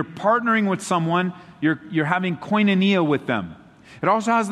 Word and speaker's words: koinonia: It's the Greek word koinonia. I'll spell it for you. K-O-I-N-O-N-I-A.